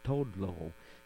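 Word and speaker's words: Toodle-Oo: The tune is entitled East St. Louis Tullio, but it's actually, I've read that it's pronounced Todlo,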